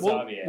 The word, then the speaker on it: Xavier: It's spelled Xavier. Kyle said Xavier, and Kyle knows his stuff, so we'll go with Xavier.